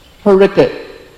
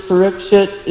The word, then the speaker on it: Parikshit: Bengalis pronounce Parikit. Okay. And is this Parikit versus Parikshit? Is that something like Prashad or Prashadam, a regional language difference?